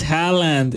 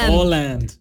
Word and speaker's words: Haaland: No es Haaland, es Holland. – Bueno, Holland. – Si tiene las dos Holland, A, se pronuncia como Holland, O.